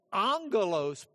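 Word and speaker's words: angelos (ἄγγελος): And then the angelos part, this is A-G-G in the Greek, but when you have two G's together, you pronounce the first one with an N. So you would be thinking in English, A-N-G-E-L.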